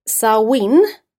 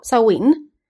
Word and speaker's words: Samhain: Samhain. Stavas inte alls så. Så det var tur att vi googlade upp och hur det uttalas. Men Samhain.